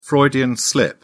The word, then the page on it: freudian slip: British English